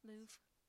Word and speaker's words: Louvre: But it hangs in the, am I saying that right? The Louvre, the Louvre, Louvre, Louvre. Sister French, here, you took French. How do you say it? – You're saying it right. Louvre, Louvre.